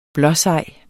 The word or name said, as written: blåsej